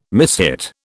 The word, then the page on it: mishit: British English